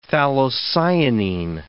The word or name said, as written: phthalocyanine